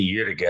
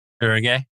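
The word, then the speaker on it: Uerige: So you pronounce the one on the river differently than I read it. I say Jürge, and you said Erge.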